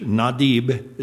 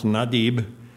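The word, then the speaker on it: Nadib (נדיב): It is spelled N-A-D-I-Y-B in English. Nadib is the way it's pronounced, Nadib.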